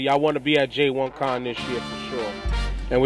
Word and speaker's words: J1Con: J1Con that's going down in Jamaica next year. Yeah, I said that right. J1Con is going to be in Jamaica next year.